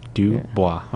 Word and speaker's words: Dubois: How do you spell that? – It's D-U-B-O-I-S. – W-A-S. Oh. – Dubois. Okay. – Dubois. – It's French.